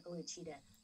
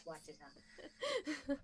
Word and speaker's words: Ouachita: I like saying Ouachita. Right? – Yes. – That's how the locals say it. Yes. Ouachita.